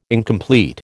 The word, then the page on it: incomplete: American English